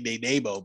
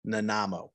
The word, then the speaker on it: Nanaimo: I want to say Nanaimo, but it's Nanaimo.